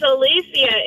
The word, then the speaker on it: Alicia: A-L-C-I-I-A. – That's Alicia. – Is it Alicia or Alicia? – It's Alicia, and he butchered my name. It's A-L-I-C-I-A.